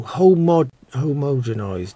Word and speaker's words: homogenised: Although, I've met a few men, some of them even farmers, who are homo. Oh dear, I can never say this right. Homo. No, homogenised. Nope. Homogenised, that's it, yep.